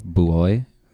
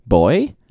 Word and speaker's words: buoy: Boy? – Yeah. – Boy. – So it's I the first thing that I that showed up online is it, the pronunciation is either buoy or boy.